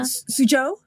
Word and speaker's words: Suzhou: Well, I also love that there's a connection with the locations with what is it pronounced? Shouzhou Garden in China? – Suzhou? Yeah, the Suzhou Garden.